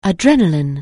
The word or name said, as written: adrenalin(e)